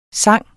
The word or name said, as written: sang